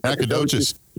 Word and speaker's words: Nacogdoches: Nacogdoches.